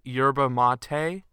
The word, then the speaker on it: yerba mate: Uh, one of your top two favorite only children, and um, your yerba mate. I think it's actually pronounced yerba mate, but um, I have a can of it right here.